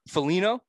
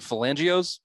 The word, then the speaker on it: Foligno: I guess you would call Nick Foligno, I think is how you pronounce it. – I think that's how you say it, yeah. – I think in the season we were pronouncing it Falangios, which close enough.